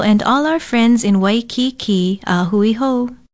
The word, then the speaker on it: Waikiki: Waikiki is spelled W-A-I-K-I-K-I with kaha ko over the last two vowels. Pronounce it Waikiki.